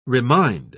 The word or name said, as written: remind